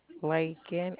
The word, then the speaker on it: Laken: Lakin, L-A-K-E-N.